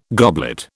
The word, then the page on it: goblet: British English